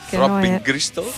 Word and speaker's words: Throbbing Gristle: In realtà si dice Throbbing Gristle. – Throbbing.